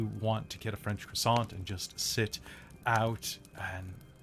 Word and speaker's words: croissant: Whenever I said that in front of Teddy, and he kind of made fun of me. He said it's croissant.